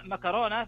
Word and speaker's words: مكرونة: خلنا ناخذها أول شيء النطق الأصح يعني وش هو أو الأكثر شيوعا هو بالكاف طبعا معكرونة هذا النطق الصحيح ويمكن الدارج في أغلب الدول. أما مكرونة فهو نطق دارج منتشر في بعض اللهجات ويستخدم كثيرا في الحديث اليومي لكنه يعني أقل يقولون أقل فصاحة من حيث التعريب المتداول. وترى